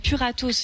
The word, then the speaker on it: Puratos: Alors, je cite, c'est dans l'entreprise Pâtisse France Puratos,